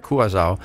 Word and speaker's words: Curaçao: Hvordan udtaler man egentlig? Vi er gået med Curaçao.